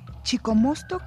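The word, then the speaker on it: Chicomoztoc: Chicomostoc, hasta ahora pronunciado Chicomostoc, fue el lugar de donde salieron las 7 tribus nahuatlacas.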